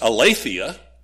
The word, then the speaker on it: aletheia: It's pronounced aletheia.